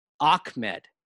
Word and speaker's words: Ahmed: The Miami pronunciation guide does say his name is pronounced Ahmed. – Oh, so yeah, and, they're getting it right. And Salvon Ahmed, eh, I, I'm looking at a story from the Palm Beach Post has pointed out to reporters that his name, his surname, is pronounced Ahmed, not Ahmed.